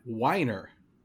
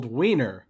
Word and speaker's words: Weiner: Weiner. Oh, it's spelled Weiner, but it's pronounced Weiner.